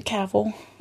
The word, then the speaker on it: Cavill: Cavill.